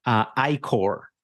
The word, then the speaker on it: I-Corps: Yeah, it's not an innovation corpse. It's Innovation Corps, even though it's spelled with a P. – So say it again. What's it called? – I-Core. It's I-C-O-R-P-S. – Okay. – The P is silent. They make sure it's not an I corpse, it's an I core.